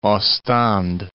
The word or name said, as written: Ostende